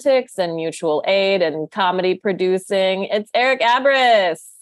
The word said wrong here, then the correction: Abriss: you were very close. It's uh, Abriss. Long. – Abriss.